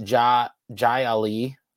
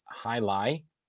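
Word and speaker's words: Jai Alai: And I always want to call it Jai Ali. But it's actually, I think, pronounced Hai Lai.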